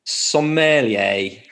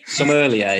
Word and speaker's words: sommelier: How do you say the word sommelier? Sommelier. – You've literally nailed it first time.